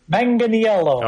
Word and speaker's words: Manganiello: Joe Manganiello. Did I get that right? – Manganiello. – Oh, I can't. – He's got it right, though. – Okay. – That's, but you know what? That's what most people pronounce it, Manganiello. – Okay, yeah, that's my Canadian. – That is the proper.